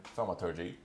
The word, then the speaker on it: Thaumaturgy: Can I use a cantrip called Thaumaturgy? – Okay. – Did I pronounce that correctly? – Probably not. – Thaumaturgy, yeah. – Thaumaturgy.